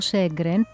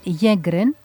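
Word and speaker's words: Sjögren: Το σύνδρομο Σέγκρεν πήρε το όνομά του από τον Σουηδό οφθαλμίατρο Χένρινγκ Σέγκρεν. Βέβαια στα σουηδικά προφέρεται Γέγκρεν, ο οποίος το περιέγραψε για πρώτη φορά το 1933.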